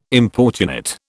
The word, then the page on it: importunate: British English